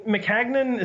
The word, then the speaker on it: Maccagnan: As for the move, I mean, Maccagnan, is that even how you pronounce it? I've never known. – Yes, it is.